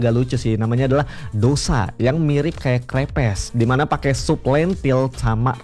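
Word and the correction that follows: crêpes: rempah teman pro 2, bukan krepes sih bacanya yang benar ya, itu crepes ya.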